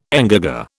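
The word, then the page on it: engagé: British English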